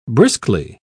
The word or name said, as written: briskly